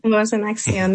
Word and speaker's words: Voz en Acción: You've launched, though, and correct me if I get this wrong, is it Voz en Acción? Did I say that right? Probably not. But Voz en Acción, that's right.